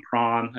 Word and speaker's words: Prahran: Um, Prahran, I think, is the correct pronunciation.